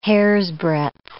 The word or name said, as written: hairsbreadth